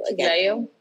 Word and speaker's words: Chiclayo: Chiclayo? – Yeah.